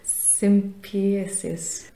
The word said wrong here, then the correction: sympoiesis: In Danish, it's called sympoiesis. – Okay, sympoiesis.